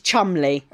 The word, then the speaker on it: Cholmondeley: Cholmondeley is spelled Cholmondeley. But it's Cholmondeley.